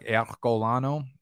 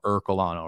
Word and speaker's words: Ercolano: So it's like, anyone who's a normal human would pronounce it like you are, the letter U, the letter R, Colano, Ercolano, right?